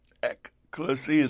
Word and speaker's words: ekklesia: That word in the Greek, ekklesia, is spelled E-K-K-L-E-S-I-A.